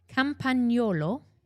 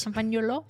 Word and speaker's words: Campagnolo: Campagnolo, is that correct pronunciation? – Pretty close. – Campagnolo.